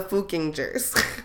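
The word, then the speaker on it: Fuckingers: Okay, and also the town people are known as the Fookingers. but it's spelled like fucking jers.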